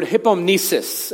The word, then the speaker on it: hypomnesis: The word reminder is this word hypomnesis. Everyone say hypomnesis. Very good. We're awake. We're alive. We're good. There's sort of two root words here. The word, it's pronounced hupo, not hippo.